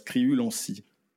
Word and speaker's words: Criulanscy: non, ça se prononce Criulancy.